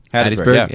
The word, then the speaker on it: Hattiesburg: I say that right? – Hattiesburg, yeah. Hattiesburg, yeah. Mississippi.